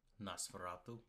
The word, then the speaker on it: Nosferatu: Nosferatu? – Yeah.